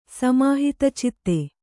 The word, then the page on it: ಸಮಾಹಿತಚಿತ್ತೆ: kannada